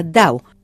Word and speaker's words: Dau: Esta fue una de las experiencias que ofreció en París Dau, que se pronuncia Dau, el apodo de Lev Landau, el científico que inspiró el proyecto.